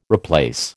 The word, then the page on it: replace: American English